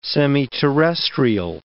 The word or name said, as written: semiterrestrial